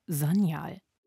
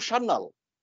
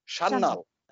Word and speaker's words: Sanyal: Natürlich wird mein Nachname auch nicht so ausgesprochen, wie ich ihn mein Leben lang ausgesprochen habe. Also Sanyal. – You are Mitu Shannal. – Shannal.